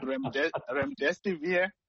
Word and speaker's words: remdesivir: It's called remdesivir.